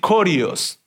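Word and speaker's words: corios (κύριος): Se pronuncia así, corios, era corios. Así se pronuncia.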